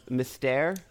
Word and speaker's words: Mystère: I know that I'm going to mispronounce this, but just to go back, the Cirque du Soleil show that I saw was called Mystère. – Oh, okay. Yeah. – Mystère. – Nice. Okay. Well, you pronounced that right.